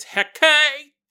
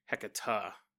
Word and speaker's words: Hecate: It's Hecate. I'm sure I'll get a couple emails like that. But such is life, such is life. I've always heard her referred to as Hecata. Even though it doesn't look like it should be pronounced that way.